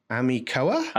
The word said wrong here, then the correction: Animoca: Animoca Brands. – Okay, there you go.